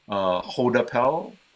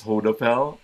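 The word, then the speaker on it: Goudappel: The webinar on Tuesday, I'm working with a company called Hoodapel. It's spelled go it's Goodapel, but the pronunciation is Hoodapel.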